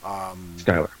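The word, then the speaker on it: Chyler: You can't lose Chrysler, if I said her name right. – Skylar. – Skylar. Chrysler.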